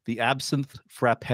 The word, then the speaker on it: Absinthe Frappé: So let's dive into your brand new book, dropping April 19th, 2023, The Absinthe Frappe.